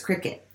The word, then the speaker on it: Cricut: It's like C-R-I-C-U-I-T, but it's Cricut.